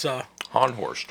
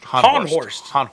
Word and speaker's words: Haunhorst: It's spelled like it's pronounced, but it's... – Honhorst. – Honhorst. – H-A-U-N-H-O-R-S-T.